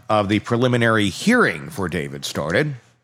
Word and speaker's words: D4VD: You've seen the D4VD and was like, what? Deforded? Deforvid? What? It's pronounced David.